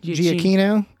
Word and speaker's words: Giacchino: How do you pronounce his name? – Giacchino.